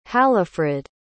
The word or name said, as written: halifrid